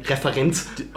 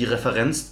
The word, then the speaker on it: Referenz: Ja, ja, du kannst einfach noch deutscher aussprechen und sagen Referenz. – Oh ja, stimmt, die Referenz zu Brecht, zur Dreigroschenoper.